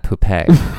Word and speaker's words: poupée: Also, Marionette is a fantastic drag name, but I would want her, it would be like Marionette Puppet, but it should be pronounced like Marionette Poupée.